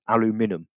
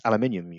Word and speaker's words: aluminium: You can't pronounce aluminum. Aluminum, sorry. Aluminum. I can't pronounce it. You're right, Dunstan. It's aluminium, you utter twats.